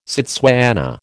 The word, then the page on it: Setswana: American English